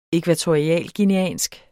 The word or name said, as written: ækvatorialguineansk